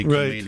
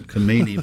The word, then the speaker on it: Khomeini: I was trying to say Khomeini. – Right. – Trying to say his name right, Khomeini, by looking at him.